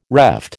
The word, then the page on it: reft: American English